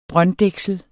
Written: brønddæksel

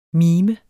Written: mimer

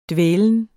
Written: dvælen

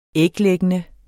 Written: æglæggende

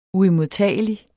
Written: uimodtagelig

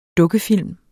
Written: dukkefilm

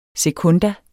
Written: sekunda

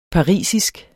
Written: parisisk

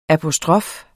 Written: apostrof

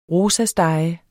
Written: ROSASTEGT

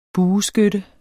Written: bueskytte

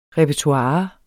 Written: repertoire

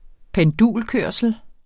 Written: pendulkørsel

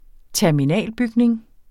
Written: terminalbygning

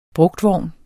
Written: brugtvogn

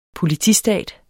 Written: POLITISTAT

